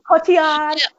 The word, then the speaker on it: Cotillard: And Leonardo DiCaprio, he was so in love with his wife, played by, now I can never say her name quite right, is it Marion Cotillard? – Cotillard.